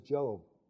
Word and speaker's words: Job: His name was Job. Job. J-O-B. How do you pronounce it? Job. I know it sounds like something else.